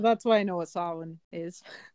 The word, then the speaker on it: Samhain: So it has its roots in the ancient Celtic festival of Samhain, which I did not know how that was pronounced until I literally researched this, because it's spelled S-A-M-H-A-I-N. – Yep. – So I always pronounce it like Samhain. – Yeah. – But it's Samhain.